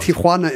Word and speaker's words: Tijuana: Tijuana is the correct pronunciation.